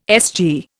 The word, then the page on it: SG: Indian